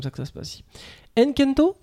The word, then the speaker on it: Encanto: Nous parlerons donc Enkento. c'est comme ça que ça se prononce Encanto. – Encanto.